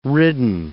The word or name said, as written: ridden